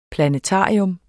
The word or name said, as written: planetarium